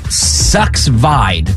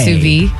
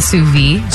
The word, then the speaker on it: sous vide: It looks like it's spelled like sucks vide. Sucks. – Wait, like a sous vide? – Is that what it is?